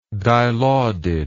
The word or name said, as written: dilaudid